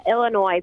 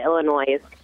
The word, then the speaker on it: Illinois: Yeah, Illinois. People pronounce it Illinois with the S at the end.